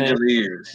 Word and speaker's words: Langeliers: His last name is Langeliers. Is that pronounced his last name? – Langeliers.